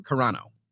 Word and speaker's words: Carano: Carano.